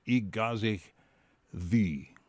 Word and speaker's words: Y Gaseg Ddu: So I'm going to take a stab at the name of the tune you heard by VRI, and it's Igazich Thi. And that's pronounced Y, G-A-S-E-G-D-D-U.